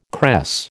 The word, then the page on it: cress: American English